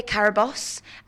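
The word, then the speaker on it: Carabosse: I play Carabos, which I used to pronounce as Carabos, with my whole accent, but got told by Nicholas, the director, that that was incorrect.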